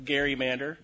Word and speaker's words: gerrymander: What are the standards for defining an egregious partisan gerrymander, or gerrymander, as some people like to say? To honor the actual pronunciation of the original Elbridge Gerry.